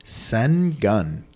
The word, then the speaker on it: Şengün: Şengün.